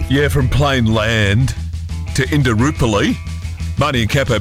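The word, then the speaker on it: Plainland: I always call it Plain Lands, but it's actually Plain Land, which doesn't sound right. – There's no S. – Yeah, there isn't an S, but we do that.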